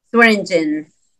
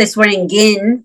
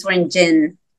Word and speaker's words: Swearingin: Swearingin. – Swearingin. – Mm-hmm. A lot of people say Swearingin, but it's Swearingin, like a gin at the end.